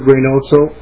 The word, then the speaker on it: Reynoso: My name is Edward Reynoso, R-E-Y-N-O-S-O, and I represent Teamsters Joint Council 32.